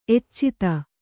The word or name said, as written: ಎಚ್ಚಿತ